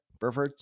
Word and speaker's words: Burford: Spencer Burford, who I know is still young. Did I say that right, by the way? Burford? – Spencer Burford, yep.